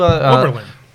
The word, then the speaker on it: Oberlin: It was Oberon, Ohio. Yeah. Overton. I don't know how to say it, but I went to Oberlin. – Oberlin.